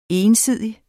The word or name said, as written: ensidig